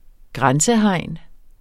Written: grænsehegn

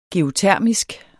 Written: geotermisk

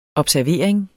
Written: observering

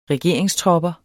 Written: regeringstropper

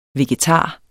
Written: VEGETARER

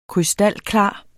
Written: krystalklar

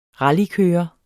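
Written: RALLYKØRER

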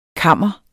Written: KAMRENES

